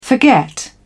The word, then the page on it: forget: British English